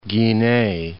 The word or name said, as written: Guinee